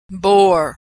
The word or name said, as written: bore